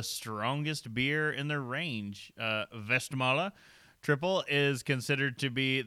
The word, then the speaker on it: Westmalle: So we have a pronunciation, Westmalle, or colloquially Westmall. Westie, if you're from Boston.